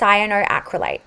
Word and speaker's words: cyanoacrylate: It's a big, long, scary word, but it is pronounced cyanoacrylate, and that is 90% of your lash adhesive.